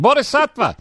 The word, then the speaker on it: Bodhisattva: Firing Line, Divining Rod, Bodavista. No, I'm not pronouncing that right. Bodhisattva. is that close, Eddie? – You're right in the middle, so just combine the two and you got it. – Bodhisattva.